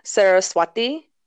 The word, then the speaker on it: Saraswati: yeah, so when I looked up like an older pronunciation of it, it said Saraswati.